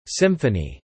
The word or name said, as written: Symphony